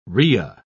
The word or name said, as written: ria